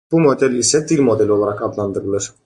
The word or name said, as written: model